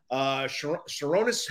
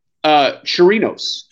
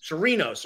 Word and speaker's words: Chirinos: How do we say his last name? Chironis? – Chirinos. – Chirinos, okay. He's placed on the 60-day injured list.